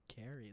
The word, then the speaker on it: Khary: Carrie? Like Carrie. – The blood one? – So, K-H-A-R-Y is how you spell it.